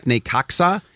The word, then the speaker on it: Necaxa: It's sort of like Necaxa. You pronounce the X in Mexico, even though everywhere else in Mexico, it's pronounced like an H.